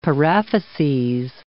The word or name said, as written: paraphyses